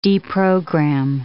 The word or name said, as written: deprogram